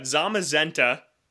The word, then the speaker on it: Zamazenta: So we got Zamazenta, and that was chosen to rhyme with magenta, going back to naming Pokemon after colors.